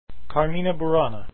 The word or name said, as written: Carmina Burana